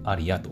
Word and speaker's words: ARIA: ー、と、アマプラとかね、ちなみにアリアっていうのはね、えっ、ー、と、 A、 R、 I、 A って、あの、まあ、カタカナで書いてもいいと思うんだけど、あの、英語で、大文字、英語の大文字で ARIA って書いて、アリアと読みます。